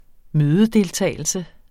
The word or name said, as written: mødedeltagelse